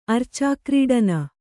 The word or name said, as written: ಅರ್ಚಾಕ್ರೀಡನ